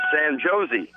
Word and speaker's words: San Jose: So he says to the kid, he says, "Hey, he says, how far is it from here to from here to San Jose?" – And, San I, Josie. and I said, "Well, they they don't pronounce it like San Jose." I said, "J's are like H's here."